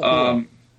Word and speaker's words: Machinarium: Machinarium? Machinarium? – Machinarium. – Machinarium? Mm-hmm. Something like that? – I say it like machinarium.